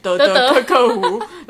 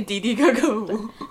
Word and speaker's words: 的的喀喀湖: 哎，刚开始我这看到那个课本的时候，完全不知道那怎么念，是念什么德德克克湖，然后我说不对不对，那是念迪迪克克湖。